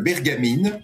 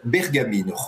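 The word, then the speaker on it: Bergamín: Oui, alors, en fait, on dit en espagnol Bergamine.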